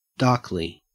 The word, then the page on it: darkly: Australian English